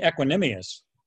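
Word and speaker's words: Equanimeous: Oh, no, you mean Equanimius. – Is that how you say it? – The Notre Dame guy? – Yeah. – Yeah, I think it's Equanimius St. Brown.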